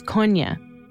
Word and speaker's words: Kony: There are, it has to do with linguistic differences in southern Uganda, why people call him Kony. – The proper pronunciation is Konya.